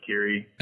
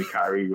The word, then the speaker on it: Khiry: This is Khiry Brothers. A lot of people say my name wrong, like Khiry, but it's definitely Khiry with an I.